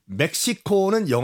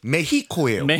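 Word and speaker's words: México: 멕시코는 영어식 발음이고 그 현지 발음은 메히코예요. – 메히코. 오늘 그럼 메히코로 가나요? – 메히코. – 네. – 왜냐하면 그 스페니쉬에서 X는 히읗 H로 발음합니다.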